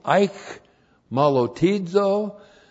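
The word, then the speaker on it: eich malotizo (αἰχμαλωτίζω): kai plus the present active participle of eich malotizo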